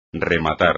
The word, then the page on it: rematar: espagnol